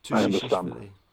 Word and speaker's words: Tushishvili: the Georgian Tushishvili. Oh, I feel like I'm adding too many syllables to his name. – Tushishvili.